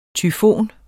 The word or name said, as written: tyfon